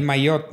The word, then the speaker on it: Maillot: Oye, entonces Ganaste el, veo, el Malet, perdón, no sé cómo pronunciarlo. El Maillot, Maillot, Blanco.